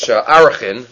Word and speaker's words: Arachin: And it comes out from our Gemara in Maseches Erechin, which Arachin, I should say, which seems to be the more correct pronunciation of Arachin, as in Eder Adarim, Erech Arachin, says the Gemara that we had on yesterday's daf.